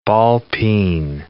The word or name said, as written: ball-peen hammer